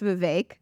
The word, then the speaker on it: Vivek: It's even better because his name is pronounced Vivek.